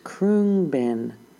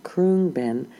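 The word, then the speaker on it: Khruangbin: It's pronounced Krungbin, but it's not spelled that way. It's K-H-R-U-A-N-G-B-I-N, but pronounced Krungbin.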